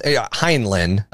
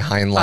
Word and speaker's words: Heinlein: Heinlein is how I pronounce it. You say Heinlein. – I've never actually heard it pronounced, so... – I don't know. Robert A. Heinlein, I'm just going to go with Heinlein.